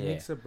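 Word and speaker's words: Janicza: Janiksa Bravo. So the C, Bravo. the C's pronounced like an S.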